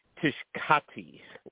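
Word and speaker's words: Tshibaka: So it is very possible that Kelly Tishkati. – I think it's like shiitake. I think it's like Japanese cuisine.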